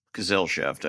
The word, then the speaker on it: Gesellschaft: but it's Tolkien Gesellschaft. – Gesellschaft, I think. So you were close.